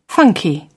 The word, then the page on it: funky: British English